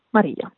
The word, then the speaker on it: Maria: And I pronounce it as they probably do in Spanish, Maria.